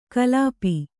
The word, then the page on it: ಕಲ್ಪಿ: Kannada